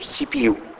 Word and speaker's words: CPU: The pronouns يعني بتنطقها منفصلين separate letters سي بي يو.